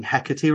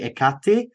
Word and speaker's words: Hecate: then I'm going to correct you and say you're saying Hecate wrong. It's actually Ekati.